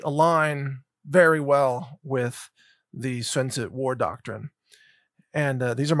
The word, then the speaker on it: Sun Tzu: I'm also going to cover a very important analysis that stems from what people in America call Sun Tzu, art of war, but it's actually in Chinese, it's pronounced Sun Tzu.